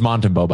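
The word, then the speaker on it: Montembeault: It's Montembeau, by the way. Yeah, that's how you say it.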